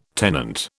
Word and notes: tenant: British English

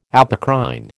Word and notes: apocrine: American English

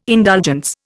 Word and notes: indulgence: Indian

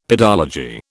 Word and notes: pedology: British English

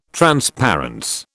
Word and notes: transparence: British English

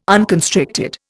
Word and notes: unconstricted: Indian

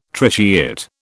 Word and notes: tritiate: British English

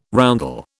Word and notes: roundel: British English

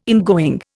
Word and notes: ingoing: Indian

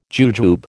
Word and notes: jujube: American English

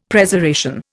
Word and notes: Preservation: Indian

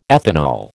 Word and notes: ethanol: American English

